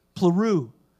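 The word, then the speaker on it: Pleroo (πληρόω): Pleroo is how it's pronounced.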